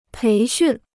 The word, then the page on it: 培训: péi xùn